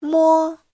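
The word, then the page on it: 摸: mo1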